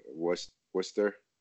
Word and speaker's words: Worcester: How do you say Worcester? You say Worcester? – Worcester. – Oh, you say it fine.